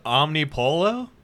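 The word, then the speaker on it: Omnipollo: Collaboration with Omnipolo? Am I saying that right? – Yeah. You're right.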